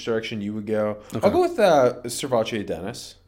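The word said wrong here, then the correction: SirVocea: I pronounced his name wrong. Servacie Dennis.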